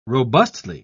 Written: robustly

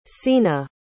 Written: cena

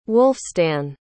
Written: wulfstan

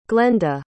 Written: glenda

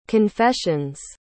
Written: confessions

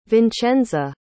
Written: vincenza

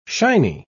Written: shiny

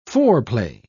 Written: foreplay

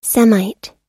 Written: semite

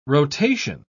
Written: rotation

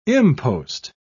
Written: impost

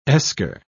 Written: esker

